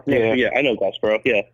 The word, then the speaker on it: Glassboro: Am I pronouncing that correctly? – Yeah, Glassboro.